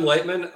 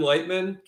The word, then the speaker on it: Leichtman: So at Kevin Leichtman, my last name is such a beast to spell. It's spelled exactly not how it sounds.